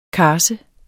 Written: karse

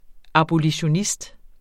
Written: abolitionist

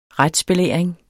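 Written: RETSBELÆRING

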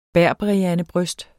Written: berberiandebryst

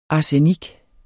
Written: ARSENIKKEN

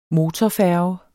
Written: motorfærge

